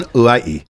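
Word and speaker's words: Ulai'i (Hawaiʻi): On Hawaii? – Not on Ulai'i. You don't pronounce that H.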